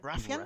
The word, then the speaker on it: Ruffian: Que Rockstar compró el estudio Ruffian o Ruffian, ¿cómo será? – Ruffian.